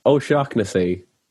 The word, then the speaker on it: O'Shaughnessy: My name's pronounced O'Shaughnessy, and it's not like the rarest of names. I guess it's not a common name. But I guess the anglicized version is Shaughnessy, but no one ever really makes that mistake.